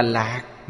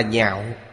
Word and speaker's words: nhạo: Chữ này không đọc là lạc, mà đọc là nhạo.